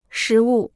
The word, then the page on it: 实物: shí wù